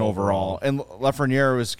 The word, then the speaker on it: Lafreniere: I can never say his name right. – Alexis Lafreniere. – I said it right. – Pretty good.